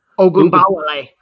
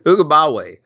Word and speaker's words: Ogunbowale: Ogunbowale. – Ogunbowale.